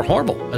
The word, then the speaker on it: Hormel: introduced by Hormel, or Hormel, as the locals say.